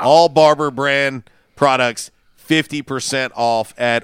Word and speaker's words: Barbour: Barber is the brand. Like, you know, you get your hair cut. Yeah. Barber. It's spelled B-A-R-B-O-U-R, but it's Barber.